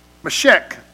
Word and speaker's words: Meshech: And he's saying, Thus saith the Lord God, behold, I am against you, O Gog, the prince of Rosh and Mehesh. How do you say that? Meshech. Meshech and Tubal.